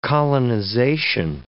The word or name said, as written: colonization